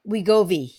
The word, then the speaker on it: Wegovy: The other one is Gobi, you said. Was that it? Gobi? – Wegovy, yeah.